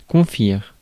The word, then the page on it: candy: [ˈkæn.di]